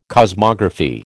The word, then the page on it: cosmography: American English